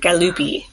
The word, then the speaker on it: Galuppi: It's spelled G-A-L-U-P-P-I.